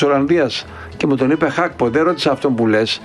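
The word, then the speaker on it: Gakpo: Χάκπο λέγεται, προφέρεται, δεν προφέρεται γκάκπο, κύριε Σιδέρη, αελάρα.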